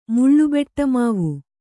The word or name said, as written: ಮುಳ್ಳುಬೆಟ್ಟಮಾವು